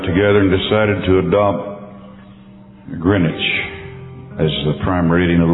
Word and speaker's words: Greenwich: Our prime meridian in the in the world is located in Greenwich. That's how they pronounce it. It's spelled Greenwich, Greenwich, uh, right out of London.